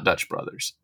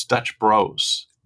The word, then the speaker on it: Dutch Bros: It's not Dutch Brothers, it's Dutch Bros. – That's the way the company pronounces its name, yes.